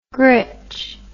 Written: gritch

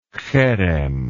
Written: cherem

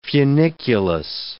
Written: funiculus

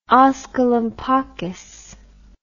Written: osculum pacis

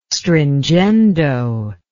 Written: stringendo

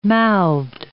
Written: mouthed

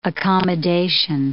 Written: accommodation

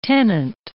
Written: tenant

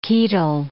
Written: chaetal

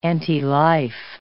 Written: antilife

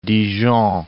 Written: Dijon

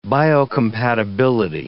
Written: biocompatibility

